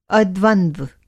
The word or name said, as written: अद्वंद्व